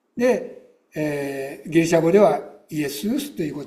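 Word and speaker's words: イ エ ス ウ ス (Ἰησοῦς): 少し名前の由来をお話ししたいと思うのですけれども、イエスという名前はギリシャ語ではイエスウスと発音します。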